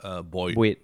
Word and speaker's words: buoyed: How the fuck do you pronounce Buoyed? – Buoyed. – Oh, not buoyed. – I mean, it's boy, what? Buoyed. Like a life boy.